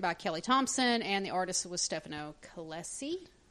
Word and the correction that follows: Caselli: Okay. – Caselli. I'm sorry, Michael.